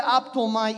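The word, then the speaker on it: aptoma (ἅπτομαι): Y la palabra este, griega que usa aquí para tocar es una palabra que se, se pronuncia aptoma y significa, traducida traducida tocar y significa literalmente conectarse con alguien o abrazar, tocarlos.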